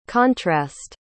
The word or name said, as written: contrast